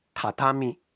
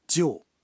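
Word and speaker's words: 畳: 在日本，碟这个汉字有两个读音，一个是榻榻米，榻榻米，一个是旧。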